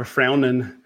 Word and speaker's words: Fraune: Yeah, we've been defending it for decades and it's wrong. No, it should be Fraune or Fraunen.